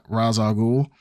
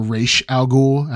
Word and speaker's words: Ra's al Ghul: It's cool for like Ra- Ra's al Ghul, mm-hmm. – but it's or Raish Al Ghul, however you want to pronounce it.